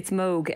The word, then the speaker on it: Moog: Now, I've been calling it the Moog synthesizer for years, but I'm told that in fact the correct pronunciation is the Moog synthesizer. – You're not alone. I think a lot of people have been calling it Moog because of the double O, but it's Moog, named after Bob Moog, its creator.